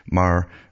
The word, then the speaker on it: Maher: I think it's Maher. I generally pronounce it Maher or Meyer, but it's Bill Maher it's pronounced.